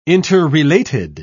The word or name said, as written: interrelated